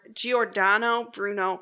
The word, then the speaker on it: Giordano Bruno: He came to this conclusion after he saw a picture of the I don't know if I'm saying this right. Giordano Bruno crater. – Yeah, that's pretty close.